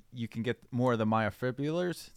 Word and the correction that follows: myofibrils: Did I say that right? – Myofibrils. – Myofibrils. – Yeah.